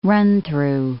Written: run-through

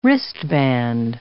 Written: wristband

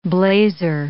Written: blazer

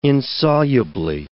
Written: insolubly

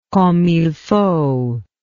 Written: comme il faut

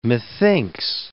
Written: methinks

